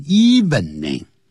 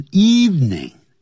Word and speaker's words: evening: 那个一很多人会念成 evening, 不是是 evening, eve, 就像除夕前一个晚上叫 eve, evening, 是这样念的 evening, 度过一个安静的晚上夜晚 at home, by herself, 独自一个人。